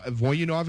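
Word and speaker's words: Voynov: Kings have drafted, Vyacheslav Voyanov, I believe is how you pronounce it, Loktionov.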